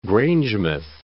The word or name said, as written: Grangemouth